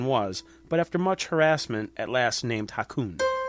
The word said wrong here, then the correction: Hakuin: I hope I'm saying his name right. – How's it spelled? – H-A-K-U-I-N. – Hakuin.